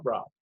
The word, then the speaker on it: Raab: I was like, that's it, Rob, Saab Rob so I got back with the, the, the label, and I was like, okay, we're gonna just call myself Rob. It's like, no, nah, man, that's not that's not deep enough. I'm like, no, we're gonna spell it R A A B.